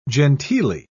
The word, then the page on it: genteelly: British English